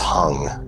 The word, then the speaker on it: hong: But as you're doing this, on the in-breath, I want you to mentally chant hung, like hung, H-O-N-G, but it's pronounced hung.